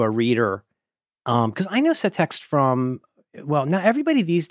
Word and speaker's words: Setext: The name of the format, I'm not sure how to pronounce it, because in the internet, when this came out, we were all reading things and not speaking them, so no one knows how it's actually pronounced. Anyway, Setext. S-E-T-E-X-T. Setext? Setext? – Setext. – Setext. – Yep.